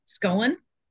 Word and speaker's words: Scollon: I'm going to get your last name, Scollon.